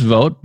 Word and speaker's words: Vogt: Debbie Vogt, pronounced Vogt, but spelled V-O-G-T.